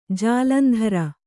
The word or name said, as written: ಜಾಲಂದ್ರ